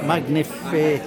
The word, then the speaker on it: Magnificat: Magnificat.